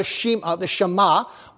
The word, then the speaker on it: Shema: This was God's way of dealing with the children of Israel in the giving of what they call the Shema, while we pronounce it Shema.